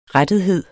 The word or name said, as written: rettethed